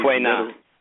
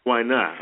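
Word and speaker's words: Tuinai (推拿): and completed apprenticeship training in Tuinai, I think that's how you say it, traditional Chinese Tuinai. – Tuinai, okay, thank you.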